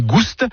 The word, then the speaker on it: goust: Ni goût ni goust, avec prononciation du S originel que l'on retrouve dans le provençal goustus, goûteux, et qui a été remplacé depuis par l'accent circonflexe dans notre orthographe moderne.